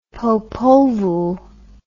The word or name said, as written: Popol Vuh